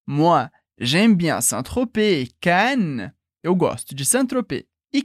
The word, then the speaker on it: Cannes: Cannes. Cuidado que em português a gente lê como se escreve, portanto, Cannes, como o Festival de Cannes. Mas em francês é sempre Cannes.